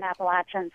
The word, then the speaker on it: Appalachia: Either pronunciation is technically correct, but in the southern Appalachians, we say Appalachia.